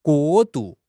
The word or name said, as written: 过渡